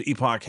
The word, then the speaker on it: Epoch: This is a story from the Epoch Times, Epoch Health. And yes, that's how you say it.